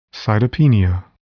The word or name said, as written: cytopenia